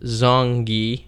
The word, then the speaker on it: Zonghi: To express opinions or severe concerns, contact Danielle Zonghi, pronounced Zonghi with a G. – I don't think you're supposed to read that part. – As in girl.